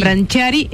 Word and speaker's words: Branciari: Y está relacionada con esta noticia de Griselda Siciliani, que estaría vinculada sentimentalmente con Emiliano Branciari, bien pronuncio. – Chari, sí. – Branciari, cantante de la banda uruguaya, no te va a gustar.